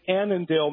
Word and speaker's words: Annandale: And just before the break, we're about to talk to Jimmy in Annandale. I don't really know if that's right. Minnesota. Is that how you say it? – Well, thank you, Steve. It's Annandale, Minnesota.